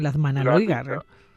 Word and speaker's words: Landmannalaugar: No sé si lo he dicho bien, sí, Landmannalaugar. – Sí, sí, bueno, lo has dicho bien dentro de lo bien que lo podemos decir quienes tenemos nuestro acento, ¿no?